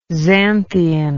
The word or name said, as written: xanthian